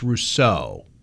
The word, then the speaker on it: Rousseau: Well, first off, it's Rousseau, mm-hmm. – with emphasis on the second syllable.